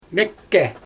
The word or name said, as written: mEkkE